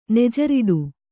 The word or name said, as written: ನೆಜರಿಡು